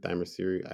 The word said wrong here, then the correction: Themyscira: I can't pronounce it. – Themyscira.